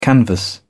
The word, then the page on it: canvas: British English